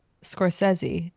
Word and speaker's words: Scorsese: Scorsese, and yes, I said it the correct and yes, way. Yes, that is the pronunciation.